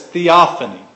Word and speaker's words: theophany: Theologians call this theophany. That's a big word. Theophany is spelled T-H-E-O-P-H-A-N-Y.